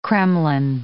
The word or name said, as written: kremlin